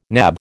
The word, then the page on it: nab: American English